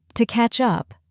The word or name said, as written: to catch up